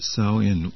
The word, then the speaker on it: Samhain: The word is pronounced Samhain with sow, rhyming with cow.